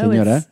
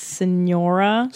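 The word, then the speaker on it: señora: And I'm sorry, you're not saying it right, though. Senora. It's senora.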